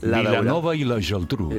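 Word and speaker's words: Vilanova i la Geltrú: Y el 12 La Daurada, Vilanova y la Geltrú.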